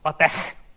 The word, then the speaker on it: LaTeX: Se kuulemma pitäisi olla sillä tavalla, että se LaTeX, joka on tämä XI tuolla, niin sen pitäisi olla niin voimallinen, että edessä oleva monitori kostuu. Virallinen määritelmä sille, mitä tuo lausutaan.